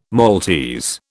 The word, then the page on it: Maltese: British English